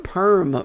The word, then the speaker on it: Perham: I was, but it's spelled like Perham, but they call it just Perm, like Big Perm.